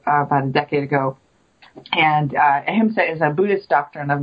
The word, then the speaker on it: Ahimsa: So the other thing that the other book that you've got is the tell me if I'm pronouncing this wrong, the Ahimsa training manual. – Yes, good job.